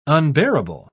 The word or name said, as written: unbearable